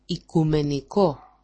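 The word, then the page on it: οικουμενικό: ikumeniko-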